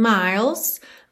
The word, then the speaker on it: miles: I'd walk 1,000 miles. Miles, atenção que você, do L, língua lá no céu da boca, você já vai pro S, tá? Não pronuncia essa letra E mesmo. Então, miles. If I could just see you tonight.